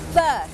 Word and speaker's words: berth: berth berth berth อ่านออกเสียงเหมือนกันกับคำว่า birth birth ที่แ